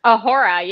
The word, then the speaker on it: Uhura: Did I say that right? – Uhura, yeah. Yeah, I mean, I'm a Star Trek fan.